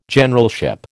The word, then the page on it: Generalship: American English